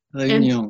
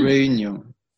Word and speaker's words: Réunion: And I kept saying, how do you say that? – Réunion. And say it again. – Réunion. – And then I said, oh, please spell it for me, R-E-U-N-I-O-N.